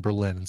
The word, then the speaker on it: Berlin: But you put the emphasis on the second syllable in Berlin, so yeah.